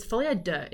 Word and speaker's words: folie à deux: It's folie, deux is how it's pronounced.